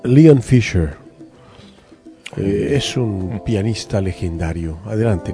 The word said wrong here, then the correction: Fleischer: Mire, Leon Fisher fue, de hecho, denominado... – Fleischer. – Bueno, pero es que es la pronunciación.